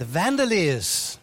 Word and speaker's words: Vandaliers: Let's welcome the Vandaliers. I'll get the mic to you in a second. Did I pronounce this name right, Jenny? – Yes, you got it.